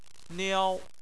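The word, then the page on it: niao: tone 1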